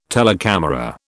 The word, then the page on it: telecamera: British English